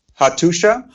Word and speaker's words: Hattusa: Hattusha. – Hattusha. Okay, cool. – It's actually spelled Hattusa, but they pronounce it Hattusha.